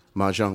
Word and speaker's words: Mahjong: Was it Mahjong? I never know how to pronounce that. – Mahjong.